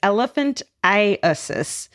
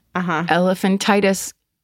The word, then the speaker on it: elephantiasis: It's not. It's elephantiasis, which causes part of the body to become grossly enlarged due to a lymphatic blockage. – We've been pronouncing elephantitis incorrectly for hundreds of years.